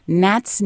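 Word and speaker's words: gnat: The first is called 99 Nats, Nits, and Nibblers. So Nat, of course, starts with a G, but it's a silent G.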